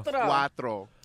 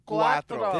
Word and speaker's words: Cuatro: No, how do you say it in Spanish, bro? – Quattro. – One more time. – Cuatro.